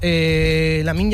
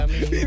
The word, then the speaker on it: Lamine: ¿Lo has dicho bien, Lamine? – Lo he dicho bien. Es que me cuesta. Lamine.